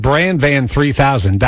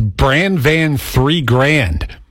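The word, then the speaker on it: Bran Van 3000: So we've been saying Brand Van 3000 that entire time. When you look at it on paper, that's how you say it. But this video theorized that maybe the band name is pronounced Brand Van 3 Grand. And when I heard that, I was like, that makes so much more sense. It kind of rhymes. There's a nice kind of flow to it.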